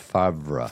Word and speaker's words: Favre: So it's pronounced Favre.